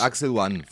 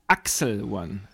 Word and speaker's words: Axel One: Ak genau, die Bedeutung, äh, die Bedeutung, die Betonung liegt auf Axel One. So wie Axel H, nur Axel One.